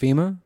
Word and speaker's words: FEMA: According to FAMI, I don't know who that is, but in 2005... – It's pronounced FEMA. – Oh, FEMA.